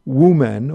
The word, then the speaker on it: Wumen: And as I said, this at this monastery there was halls connected to Wumen or Mumon. Again, I'm pronouncing it both in the Chinese way and the Japanese way of pronouncing his name, who lived there and who put together a collection of 48 koans.